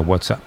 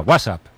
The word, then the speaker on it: WhatsApp: WhatsApp. – WhatsApp. No, peor aún, con acento en la primera. WhatsApp, ¿no? – Un WhatsApp.